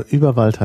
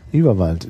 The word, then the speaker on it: Überwald: Also Überwald heißt auf Englisch Überwald. – Oh, praktisch. – Überwald. German pronunciation Überwald.